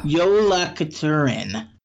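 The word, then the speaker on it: Jólakötturinn: Yes, and I'm just looking on how to pronounce it here. – Yeah, I gave up. – Yola Katurin. Thank you, Google Translate.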